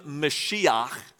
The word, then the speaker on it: Mashiach: They called him Mashiach, or we pronounce it Messiah.